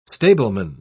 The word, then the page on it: stableman: British English